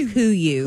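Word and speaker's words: KUIU: It's pronounced Kuyu.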